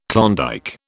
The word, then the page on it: Klondike: British English